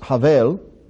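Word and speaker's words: Havel (הֶבֶל): Now, his brother Abel, in Hebrew it's pronounced Havel, means a vapor or a mist.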